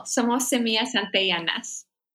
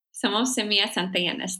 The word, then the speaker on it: Somos Semillas Antillanas: And I think you're going to help me pronounce this, but Somos Semillas Antillanas in Cabo Rojo. Is that close? – It's beautiful. Somos Semillas Antillanas. Somos Semillas Antillanas.